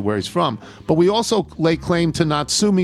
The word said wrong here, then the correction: Natsume: Soseki, that's pronounced Natsume, who is probably Japan's most renowned author.